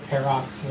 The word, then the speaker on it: Pharrox: P-A-R-R-O-X. – That works fine. P-A-R-R-O-X. – Yeah, Parrox. – With a silent H. – Yeah, with a silent H in there somewhere. P-H-A-R-R-O-X. Parrox.